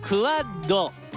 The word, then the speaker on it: QUAD: QUAD と書いてクワッド。